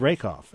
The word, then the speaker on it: Rakoff: And that's the late David Rakoff.